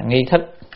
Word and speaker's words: nghi thức: Lệ nghi thức của việc đó, cái này đọc là nghi thức của việc đó phải như vậy, phải như vậy, chứ không phải là tự nhiên mình thích làm gì làm đâu.